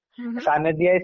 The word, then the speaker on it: Sanidhya: Sanidhya is spelled as SANIDHYA, and I have a Facebook page by the same name.